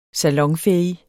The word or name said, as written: salonfæhig